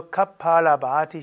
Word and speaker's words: Kapalabhati: Also Kapalabhati langes A, Kapala Bhati, und es ist weiblich, also die Kapal, Bhati.